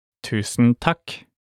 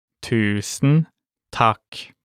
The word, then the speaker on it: tusen takk: Thank you very much in Norwegian is tusen takk. Tusen takk. Let's break it down by syllable. Tusen takk. Now let's hear it one more time. Tusen takk.